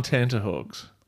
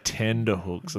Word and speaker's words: tenterhooks: I'm waiting and I'm on tenterhooks. – Okay. Thank you for correctly pronouncing tenterhooks, by the way. – Yes. What do you think? I'm going to say tenderhooks or something like you some might.